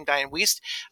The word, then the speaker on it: Wiest: That's how you say her name, Diane Wiest.